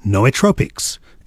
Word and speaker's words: Nootropics: Although it says here it's pronounced Nootropics.